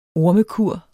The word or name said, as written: ormekur